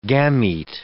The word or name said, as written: gamete